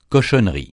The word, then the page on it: cochonnerie: francais